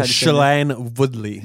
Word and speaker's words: Shailene Woodley: Shailene Woodley. How do you say that one? – Shailene Woodley.